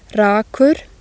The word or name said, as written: rakur